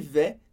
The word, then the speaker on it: vas: Tu vas, a gente escreve V-A-S e pronuncia simplesmente va.